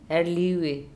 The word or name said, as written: Erliube